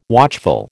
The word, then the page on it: watchful: American English